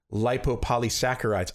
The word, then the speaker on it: lipopolysaccharides: They traced the difference to a gram-negative bacteria that had certain lipopolysaccharides.